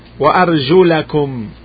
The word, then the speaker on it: وَأَرْجُلَكُمْ: First one, يا أيها الذين آمنوا إذا قمتم إلى الصلاة فاغسلوا وجوهكم وأيديكم إلى المرافق وامسحوا برؤوسكم وأرجلكم وأرجلكم. Listen carefully to the pronunciation. There is a fatha. The movement is a fatha on the lam.